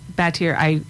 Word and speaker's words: Batyr: Batir, I know it's spelled B-A-T-Y-R.